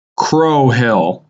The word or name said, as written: Crow Hill